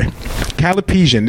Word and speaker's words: Callipygian: Um, uh, Callipygian, Sorry. Callipygian. This is spelled C A L L I P Y G I A N.